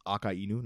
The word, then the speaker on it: Akainu: Like, it's Akainu, not Akainu, because it's Aka as in red, and Inu meaning dog, because he's a red dog.